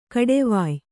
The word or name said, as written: ಕೊಡುವಾಯಿ